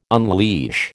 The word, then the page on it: unleash: American English